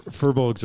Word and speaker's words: Firbolg: Firbolg did. – Yes. – Firbolg. Never heard of that. – Those are awesome. Firbolgs are one of my firbolg? Favorite classes. Yeah. – Bolg, is it? – Firbolg. – Yeah. How do you spell that? – F-I-R-B-O-L-G.